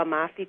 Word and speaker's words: Amalfi: I've never been to the Amalfi Coast. Am I saying that right? – Oh, yes.